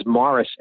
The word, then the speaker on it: Maurice: And, and there's so many in jokes in that because his, his name was Morris Evans. In England, they don't call anything Maurice. That's a French pronunciation. His name was Morris Evans. But for the show, they call him Maurice.